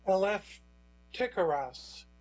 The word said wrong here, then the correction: Eleftherakis: that's Eleftheraks, Eleftherakis, I think is the correct pronunciation,